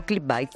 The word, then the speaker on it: Clickbait: ¿Clickbait o clickbait? ¿Cómo se dice? – Clickbait. – Bait, lo he dicho bien, bien.